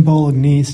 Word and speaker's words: Bolognese: Logan Bolognese, just like the pasta.